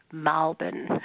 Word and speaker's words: Melbourne: So there's a sound change in Australian English as used in Melbourne, around Melbourne, whereby the name of the city of Melbourne is actually pronounced more like Malbon if you are from Melbourne.